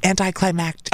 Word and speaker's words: anticlimactic: It's climactic. There's another C in the end of it, which is so, there's like two C's, anticlimactic. Oh, it's so annoying. – I think I say it wrong. – Everybody says it wrong, though. Everybody says it wrong. – Yeah, I say it wrong. – There's an extra syllable in there.